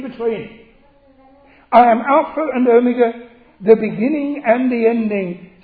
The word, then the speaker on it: Omega: The English use of the word Omega is not what you should read when you're reading the scripture. It's a long O, it's Omega, as in megaphone, the great O.